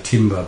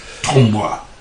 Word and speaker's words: tombola: I, I, some people say timber, but the correct pronunciation, as you know, is tombola.